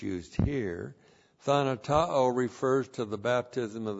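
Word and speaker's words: thanatao (θανατόω): T-H-A-N-A-T-O long O, thanatao.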